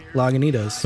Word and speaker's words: Lagunitas: Did I pronounce it right? I did, right? – Yeah, Lagunitas.